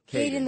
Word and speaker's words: Caiden: His name is Holden the Crown Holder. – Caiden the Crown Caiden Holder. the Crown Holder. How do you say it? – Caiden the Crown Holder.